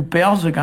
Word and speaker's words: Pevarzeg: Mais là, bon, j'avais parlé un petit peu des années en 14, hein, du mot Pevarzeg, hein, qui est 14, qu'on prononce nous Perzeg.